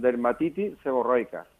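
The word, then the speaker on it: dermatitis seborreica: Mm, yo, a mí me han diagnosticado mm, dermatitis seborroica.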